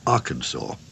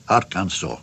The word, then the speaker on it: Arkansas: The French called it Arkansas Post, and we have no doubt that the French pronounced it Arkansas. The English, the Scots-Irish, and the other later settlers simply copied, roughly, the French pronunciation.